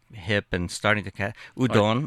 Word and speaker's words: udon: And the Caucasian server, young woman, said, oh, you mean udon? And I had to correct her in a Japanese restaurant. No, it's pronounced udon.